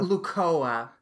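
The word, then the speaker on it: Lucoa: I'm pronouncing her name right. Luka? Lukoa? – Lukoa.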